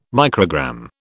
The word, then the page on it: microgram: British English